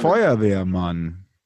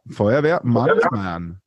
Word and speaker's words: Feuerwehrmann: Feuerwehrmann. – Say it again. – Feuerwehr. Feuerwehrmann.